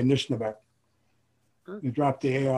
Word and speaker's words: Anishnaabeg: Anishnaabeg, yeah. – Anishnaabeg.